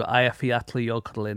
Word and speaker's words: Eyjafjallajökull: And then I went to Eyjafjallajökull in Iceland.